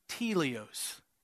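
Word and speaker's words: teleos (τέλειος): He used a word that's pronounced teleos, and it just means complete and satisfied.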